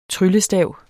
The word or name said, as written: tryllestav